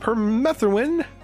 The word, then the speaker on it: permethrin: So we are going to talk with Pina again, and we're talking about permethrin. That's how you pronounce it. Permethrin.